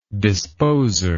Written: disposer

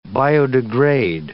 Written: biodegrade

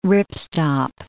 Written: ripstop

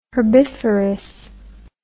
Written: herbiferous

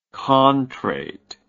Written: contrate

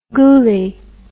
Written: goulie

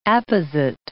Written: apposite

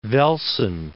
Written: Velsen

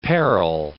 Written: peril

